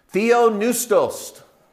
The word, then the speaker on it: Theopneustos: Theopneustos. Just ignore the silent P in the middle. New, P-N-E-U,